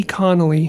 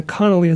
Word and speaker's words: Connoley: But they can also email me at T Connolly, which is T, and then the Connolly is spelled C-O-N-N-O-L-E-Y, at UCCSWC for United Church of Christ Southwest Conference.